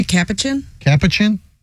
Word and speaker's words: capuchin: Capuchin? Is that how you say this kind of monkey? – Capuchin.